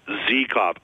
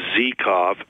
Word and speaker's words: Zykov: Zykov. I know it says Zykov on the NHL pronunciation chart, but the Carolina Hurricanes broadcasters have been referring to him as Zykov for the last couple seasons.